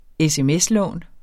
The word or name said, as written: sms-lån